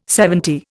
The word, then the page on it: seventy: Indian